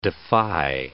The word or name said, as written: defy